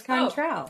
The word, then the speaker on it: Cointreau: I'll spell it. – Okay. – C-O-I-N-T-R-E-A-U. – Contrao? – Sure. – Yeah, it's Contrao.